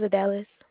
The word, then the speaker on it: Idalis: My name is Adalis. – Can you spell that for me, please? – I-D-A-L-I-S. – I-D-A-L-I-S? – Yes, ma'am.